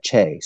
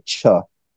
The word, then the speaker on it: Che: I always pronounce her name Hannah Che, C-H-E. She only finally corrected me after I've known her for like years. She pronounces it Chuh.